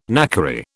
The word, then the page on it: knackery: British English